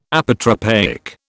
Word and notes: Apotropaic: British English

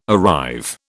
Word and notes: arrive: British English

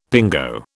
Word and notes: pingo: British English

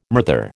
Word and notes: murther: American English

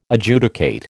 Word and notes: Adjudicate: American English

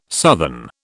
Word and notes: southern: British English

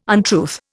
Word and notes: untruth: Indian

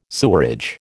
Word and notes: sewerage: American English

